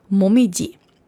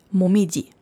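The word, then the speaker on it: Momiji (もみじ): Die japanische Lesung ist Momiji. Momiji. Die chinesische Lesung ist Koyo.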